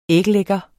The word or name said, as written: æglægger